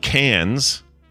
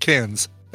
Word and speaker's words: Cairns: This is C-A-I-R-N-S, right? – Correct, yes. – That if that is spelled Cairns, then that's effed. – It's pronounced Cairns.